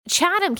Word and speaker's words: Chatham: I originally pronounced Chatham, and he corrected me. It is Chatham County. I did not realize that. Apparently, it's like a British word, but I did know it's DeKalb because I used to live in Georgia too, and I remember that.